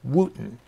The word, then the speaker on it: Wooden: His last name is spelled, by the way, W-O-O-D-E-N, but it's pronounced Wooten.